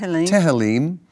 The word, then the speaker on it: Tehillim: Tehillim.